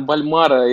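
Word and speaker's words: Bolmaro: Э, это у нас э, Леандро Больма, Больмао, если я правильно произношу, поправь меня. – А, да, Леандра Бальмара, и ты совершенно Бальмара.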